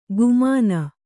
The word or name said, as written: ಗುಮಾನ